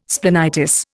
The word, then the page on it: splenitis: Indian